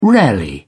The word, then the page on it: rarely: British English